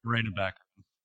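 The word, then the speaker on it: Morena Baccarin: But is it Monica Baccarin? I'm not sure how you say her name. Marina, Marina Baccarin.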